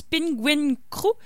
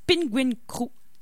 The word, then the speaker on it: PNGWNG: Donc Simon Roy nous propose cette semaine un artiste dont j'avais beaucoup de difficultés à lire le nom du groupe, mais je pense que ça se prononce Penguin Crew.